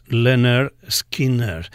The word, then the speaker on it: Lynyrd Skynyrd: Anem a punxar una cançó del primer àlbum dels Lynyrd Skynyrd, que precisament es deia pràcticament com ells, però entre parèntesis indicaven pronouns Lynyrd Skynyrd.